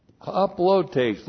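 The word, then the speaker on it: haplotes (ἁπλότης): Haplotes looks like that in the Greek, H-A-P-L-O-T, long E-S, haplotes.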